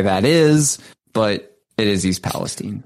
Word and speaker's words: East Palestine: Uh, I do have to shout out chat right now very quickly, who is uh, calling me out for mispronouncing Palestine. – Yeah, that's right. Yeah. I wasn't going to say anything, but yeah, he, the person in the chat is absolutely right. It's East Palestine.